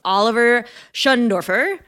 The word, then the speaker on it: Oliver Schöndorfer: Oliver Schundorfer, I figured out how to say his name, guys.